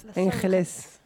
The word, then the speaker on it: Los Angeles: Euh, moi, la dernière fois que j'ai dit Los Angeles, on m'a engueulé parce que c'est euh, c'est pas la ville, mais c'est les anges. Et du coup, il faut faire la prononciation espagnole. Los Angeles.